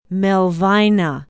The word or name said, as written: melvina